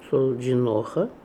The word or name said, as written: Soldjinoha